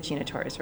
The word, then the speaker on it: Gina Torres: I said Gina Torres, right? Yeah, I said her name right.